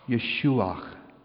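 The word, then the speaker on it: Yeshua: It is pronounced in Hebrew and Aramaic as Yeshuach.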